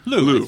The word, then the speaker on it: Liu: Lou, I think. – Liu? – Simu Liu. – L-I-U. – Yeah, anyway, I think that's pronounced Lou.